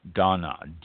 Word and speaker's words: Dana: Dana, D-A-N-A, pronounced Dana, D-A-H-N-A-H, Dana.